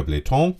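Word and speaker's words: Blähton: Wenn du ganz sicher gehen willst, legst du darüber noch, ich sag mal so 4 cm, 5 cm Bläton. So heißt das. Ich hoffe, ich habe das richtig ausgesprochen. Bläton, so heißt es. Bläton oder Bläton. Ja, je nachdem, wie man mag.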